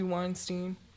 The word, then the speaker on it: Weinstein: Harvey Weinstein.